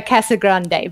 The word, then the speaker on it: Casagrande: Well, I'm going to make you feel slightly better because I don't even say it correctly myself. I am very Aussie in the way that I say it. Essentially, it's Alicia Casagrande. You can roll the tongue, but please don't.